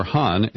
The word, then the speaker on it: Khan: And AQ Khan, or Han, as his name is pronounced in Pakistan, has been permitted to go into retirement.